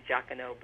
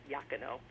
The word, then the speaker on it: Iacono: I always want to say Iacono, but in Italian it's Iacono, who is actually a brother-in-law of Vitaliano Pozzella.